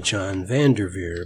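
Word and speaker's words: Jon Van Der Veer: And I'll spell that. John is J-O-N. Van is capital, of course, V-A-N. Der is capital D-E-R. And Veer is capital V-E-E-R. I'll be pronouncing it John Van Der Veer, but I wanted you to know how to spell it.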